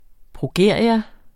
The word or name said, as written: progeria